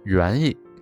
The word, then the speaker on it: 言语: 发音为元意。